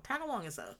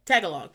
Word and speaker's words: Tagalog: Is it Tagalog or Tagalong? Tagalong Tagalog. Tagalong is a name of a Girl Scout cookie. But Tagalog. he's having this conversation in Tagalog.